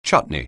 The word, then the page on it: chutney: anglais